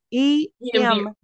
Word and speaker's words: EMDR: It's EMDR, sir. Don't miss. – EMR. – EMDR. – EMDR. I Yes. – keep saying it wrong.